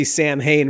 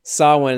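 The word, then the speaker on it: Samhain: That's cool that he pronounces Sawin correctly. Like in the second film, we see Sam Hain written. They don't really know how to say it. Sawin is how you pronounce that.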